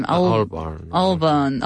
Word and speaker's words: Albarn: Albarn.